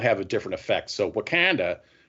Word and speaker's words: Wakanda: So they're different locations in the Marvel universe, like, you know, Wakanda.